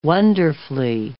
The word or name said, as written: wonderfully